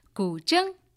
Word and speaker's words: guzheng: Wu Fei, live in Studio C, with an improvisation on her very beautiful 21-string Chinese zither, the guzheng. Would you pronounce it properly for us again? – Guzheng. You did it perfect, Bill.